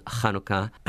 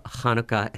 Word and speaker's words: Hanukkah: So actually, in Hebrew, it's Hanukkah. You have to, what's called, linguists call this the aspirated H. So Hanukkah, if you can get that, that's the most correct pronunciation.